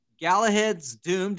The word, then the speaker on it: Galahad's Doom: He is the author of Galahad's Doom.